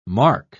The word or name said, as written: marc